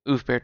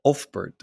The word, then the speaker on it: Ulfbert: And do you say Oofbert device because you play it and you go oof? So I always said Ulfbert device with a really hard L that after that U.